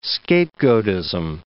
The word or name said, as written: scapegoatism